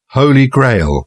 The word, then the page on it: holy grail: British English